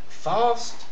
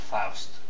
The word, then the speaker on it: Faust: How do you spell that? Fast? – Faust. – F-A-U-S-T.